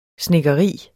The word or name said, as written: snedkeri